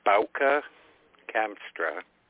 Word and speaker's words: Bauke Kamstra: Bauke Kamstra. – Bauke Kamstra. Okay, I'm writing this down phonetically. – Yeah, that's bow like is in bow wow and ka like kaka, so let's not go there.